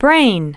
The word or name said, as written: brain